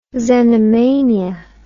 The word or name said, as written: xenomania